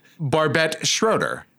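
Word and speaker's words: Barbet Schroeder: How do you say the director's name? – I say it, Barbette Schroeder.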